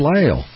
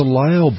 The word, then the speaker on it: Lisle: It's Lisle. It's a Lisle brand. And that's spelled L-I-S-L-E.